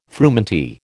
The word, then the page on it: frumenty: American English